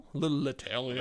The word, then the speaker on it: Leterrier: So, directed by Louis Leterrier. – Leterrier.